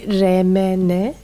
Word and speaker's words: R.M.N.: Biri Fabelmanlar, biri R.M.N. Bu arada doğru telaffuz edemiyor olabilirim. – Yo, doğru.